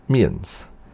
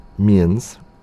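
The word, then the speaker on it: Mi'ens: The first band next week is called Mians. I think that's how you pronounce it. It's like mittens, but without the T's. Like M-I, little apostrophe, E-N-S. This is a song from the band called Mians.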